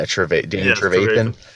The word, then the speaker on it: Trevathan: Yeah, Trevathan.